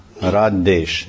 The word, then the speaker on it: Radhesh: It's called Radhesh. It's long A, Radha, not Radha.